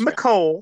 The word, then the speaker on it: Mecole: Wide receivers, Mecole, how do you say Mecole Hardman? How the fuck is that guy's name? – Mecole. – Yeah, that's Mecole. Right. I was going to say Mecole.